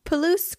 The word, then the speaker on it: Palouse: Palouse. – It looks like Palouse. Very cute little town. If you ever find yourself there, my cousin owns the Palouse Caboose.